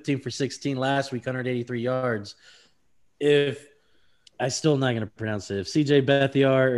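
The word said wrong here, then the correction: Beathard: or Bethard. – Just say it right, bro. – I can't even. – Like every time you say it like twice. Just say it Bethard.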